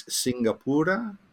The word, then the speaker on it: Singapura: And it represents Singapore's original name, which I understand is Singapura.